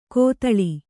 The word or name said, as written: ಕೊತ್ತಳಿ